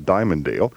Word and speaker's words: Dimmondale: Also on 1170 WXLA in Diamonddale, Washington, although it's spelled Dimmondale, it's pronounced Diamonddale.